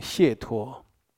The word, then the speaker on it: 解脱: 佛门常常讲一个名字叫解脱。啊，或者念成解脱。这个解脱呢，就是你对这个人事物，或者这一回事，这个状况不迷惑，你已经看懂明白，你才从这个状况解脱出来。